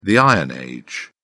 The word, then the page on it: iron age: British English